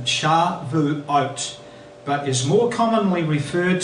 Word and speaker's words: Shavuot: This weekend it's called, in Hebrew, Shavuot. That's how it's pronounced.